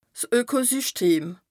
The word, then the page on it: 's Ökosyschtem: Bas Rhin